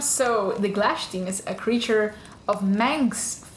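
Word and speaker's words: Glashtin: But as I've heard in some YouTube videos, it's actually pronounced Glashtim.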